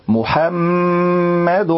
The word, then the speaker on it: محمد: طيب جميل جدا، وكمان ما ضمتش اللام رسوله لو، لكن انت قرأت وقلت رسول الله رسول الله وما فتحتش الحاء من محمد فتح سليم، وما فتحتش الفم معاها يعني أثناء النطق، قلت محا والصح تقول محا بفتح الفم محمد رسول الله رسول الله.